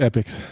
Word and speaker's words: Epix: It's on, I don't know how to pronounce the service, Epix? Epix? – Mm-hmm. Epix.